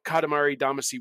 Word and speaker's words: Katamari Damacy: But obviously, these Encore editions would have a lot in common with Katamari Damacy Reroll. So, did I say it wrong? – No, you're all right.